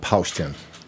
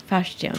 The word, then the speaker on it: Paustian: So first question, did I say that restaurant name right? How do you pronounce it? – You said it perfectly. It's called Postion. – Postion.